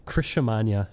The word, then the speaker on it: Criscimagna: Criscimagna. Rhymes with lasagna. It's Italian. – I mean, it kind of rhymes with it. It's more syllables.